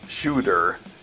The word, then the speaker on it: Tsjuder: Nah, I'm just joking. The band's name is actually pronounced like Tsjuder, and this is their sixth full-length studio album titled Helvegr.